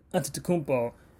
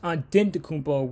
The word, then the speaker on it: Antetokounmpo: The Greek pronunciation is Antetokounmpo with a T. And then the Nigerian pronunciation is Antetokounmpo, which is with a D.